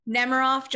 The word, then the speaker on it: Nemiroff: Nope. Nemiroff, just like the vodka.